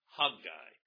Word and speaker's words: Haggai: Actually, if you were to pronounce it as the Jews would, it would be Haggai, Haggai. Actually, the emphasis needs to be on the first syllable, Haggai, but I'll go down the middle and just call it Haggai.